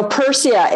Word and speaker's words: Pyrsia: So Persia, it's spelled P-Y-R-S-I-A.